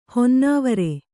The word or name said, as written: ಹೊನ್ನವರಿ